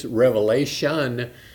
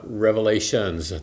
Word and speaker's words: Revelation: We've been reading the book of Revelation. This book you're reading today gets mispronounced frequently. This is a pet peeve of mine, I know, but it's Revelation, not Revelations. There's no S on the end.